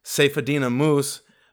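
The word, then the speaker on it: Saifedean Ammous: I can't pronounce his last name exactly right. – Seyfedin Amos. – Seyfedin Amos.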